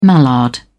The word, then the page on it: mallard: British English